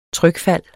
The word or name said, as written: trykfald